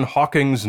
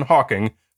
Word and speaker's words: Hawking: I don't know why this is, but whenever I hear a pseudoscientist invoke the name of Stephen Hawking for any reason, they almost always get his name wrong. It's either Hawken, Hawkins, or Hawkings. Almost never do they get it correct as Hawking.